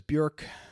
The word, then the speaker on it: Björk: You may call her Björk, but it's actually pronounced Björk. It rhymes with work.